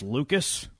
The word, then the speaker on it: Lukes: The dude who spells his name Lukes, but it's pronounced Lucas.